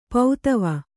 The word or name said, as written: ಪದುವ